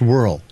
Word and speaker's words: whorl: The circular pattern of hair at the crown of your head is called the hair whorl. And I say whorl because it has an O in it. But it's still pronounced whirl.